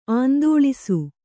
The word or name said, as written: ಆಂದೋಲಿಸು